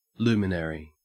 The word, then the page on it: luminary: Australian English